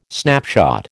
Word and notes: snapshot: American English

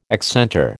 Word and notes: accentor: American English

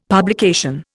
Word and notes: publication: Indian

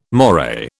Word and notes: moray: British English

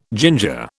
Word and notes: ginger: British English

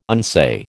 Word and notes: unsay: American English

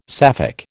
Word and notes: Sapphic: American English

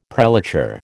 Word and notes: prelature: American English

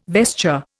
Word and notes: vesture: Indian

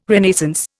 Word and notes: Renaissance: Indian

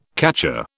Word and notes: catcher: British English

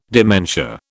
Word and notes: dementia: British English

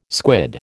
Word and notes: squid: American English

